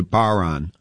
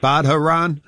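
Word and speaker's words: bodhrán: the Irish drum is a baron, and it's pronounced, or it's spelled BODHRAN. So people are always saying, you playing that Badharan?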